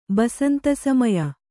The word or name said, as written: ಬಸಂತಸಮಯ